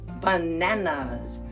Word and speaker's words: bananas: Our next fruit, B-A-N-A-N-A-S, bananas, bananas. The emphasis is on the second syllable, bananas.